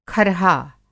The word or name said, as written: खरहा